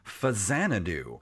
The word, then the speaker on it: Faxanadu: I never really knew how to pronounce this game until reading online how to actually pronounce it, but it's actually pronounced Faxanadu.